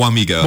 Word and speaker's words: Wamego: Wamego, am I saying that right, for your high school? Wamego. – Wamego. Okay, yeah.